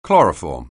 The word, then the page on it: chloroform: anglais